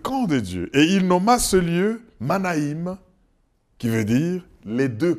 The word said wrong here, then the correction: Mahanaïm: Manaïm, Mahanaïm, je prononce bien maintenant, Mahanaïm, les deux camps.